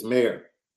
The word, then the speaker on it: Mayer: Yeah, Remember that the correct pronunciation is mayor, like mayoral candidate, not mm-hmm. mayor.